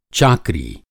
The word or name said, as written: चाकरी